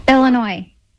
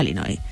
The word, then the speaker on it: Illinois: Él, español, ella estadounidense, concretamente de Illinois, y fue quien grabó este audio para que escuchemos cómo se dice realmente. – Aquí te doy la vuestra. – Illinois. – Vamos a poner la parte corta. – Illinois. – Illinois. No Illinois. Bien.